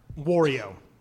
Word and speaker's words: Wario: Wario.